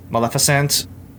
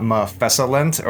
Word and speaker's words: Maleficent: It's like when I first learned that Maleficent was not Mephesalent or whatever. Like, I had been pronouncing it wrong my entire life. I'm just like, wait, hold on a second. The F and the L are switched, and it took me years to, like, train my brain to, like, fix it.